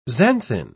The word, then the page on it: xanthine: British English